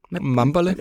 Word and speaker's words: Mbale: Så da du øh, tog til, nu skal jeg udtale det rigtigt, Mbale? Mambale. Mambale. Mambale.